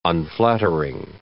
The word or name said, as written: unflattering